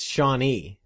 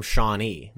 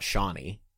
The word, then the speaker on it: Shawnee: It's Shawnee. You have to put the emphasis on the E. Like, I'm from Shawnee, not Shawnee.